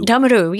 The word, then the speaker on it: damaru: Damaru, yeah, it's a damaru. Yeah, that's how we would say it. Damaru. – Sorry, damaru, I'm mispronouncing it.